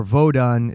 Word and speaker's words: Vodun: Vodun, which is or Vodun. It's pronounced two different ways,